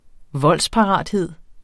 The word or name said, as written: voldsparathed